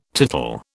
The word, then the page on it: tittle: British English